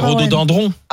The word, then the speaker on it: rhododendron: Attends, deux secondes, parce qu'on dit rhododendron. – Dindron. – C'est piégeant. – C'est dendron. Alors c'est rhododendron.